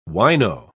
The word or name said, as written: wino